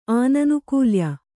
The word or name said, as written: ಆನನುಕೂಲ್ಯ